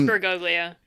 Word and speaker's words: Bergoglio: It's just Bergoglio. Am I pronouncing that right? – Yes, Bergoglio.